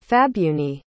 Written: fabunni

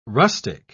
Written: rustic

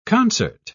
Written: concert

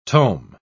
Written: tome

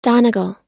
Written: donegal

